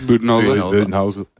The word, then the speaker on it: Budenholzer: Budenholzer? – Budenholzer.